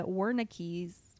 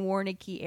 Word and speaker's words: Wernicke: Wernicke's. Why is there a Z at the end of that pronunciation? There's no Z whatever, the Braca and Wernicke area.